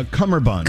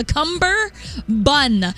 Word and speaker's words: cummerbund: This whole time I thought it was a cumber bun, like the end of cucumber and that cute little thing that I love to eat.